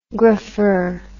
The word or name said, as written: grapher